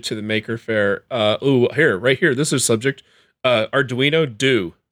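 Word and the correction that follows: Due: Talk to me about but the it's Dewey. Not pronounced do. No, it's Dewey.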